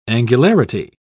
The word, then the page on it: angularity: British English